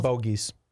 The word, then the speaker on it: Bogeys: Bogies. – How do you spell that? – B-O-G-E-Y-S. Bogies like in golf, like I had a bogey.